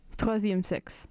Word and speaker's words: Troisième Sexe: Troisième Sexe.